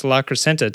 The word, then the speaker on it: La Crescenta: October 19th, Joe's Mini Bike Reunion happening in La Crescentia, California. There's no I in there. Since I was mispronouncing everything else, I thought I'd mispronounce La Crescenta to La Crescentia.